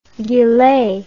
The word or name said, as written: gilet